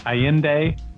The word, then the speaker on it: Allende: Miss Allende, I hope I said that right.